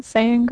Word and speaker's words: Saiyan: Saiyan Girl? – It's Saiyan Girl, because that's a reference to the fact that Goku is a Saiyan. He becomes a Super Saiyan.